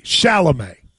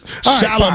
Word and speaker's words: Chalamet: Close. Chalamet. – Why are you talking to me like you're a third grade teacher, man? Like, Chalamet.